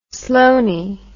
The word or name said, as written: sloanie